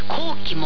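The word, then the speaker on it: 紅旗: この他東風以外に中国では紅の旗と書いて高貴も、はい、もう昔からの高級車ブランドですね。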